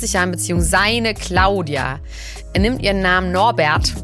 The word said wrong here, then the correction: Norberg: Norberg. Norbert. Norberg. Du kannst das nicht immer falsch aussprechen.